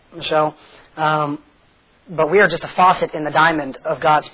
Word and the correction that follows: facet: Facet? Faucet? I don't know how you say it. Facet, you were right. She said, I think you're saying that wrong.